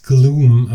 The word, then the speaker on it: Glüme: Äh, es schreibt sich Glüme, aber wahrscheinlich wird es Gloom ausgesprochen. Der, der Umlaut, der Metal-Umlaut wird ja meistens nicht gesprochen.